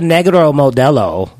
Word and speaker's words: Negro Modelo: And well, it's called a Negro Modelo.